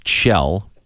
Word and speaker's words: Kjell: K-J-E-L-L is pronounced Chell. – Yes. – And I figured out the last part. Bjorgen, B-J-O-R-G-E-N.